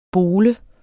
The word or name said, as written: BOLET